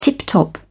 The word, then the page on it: tip-top: British English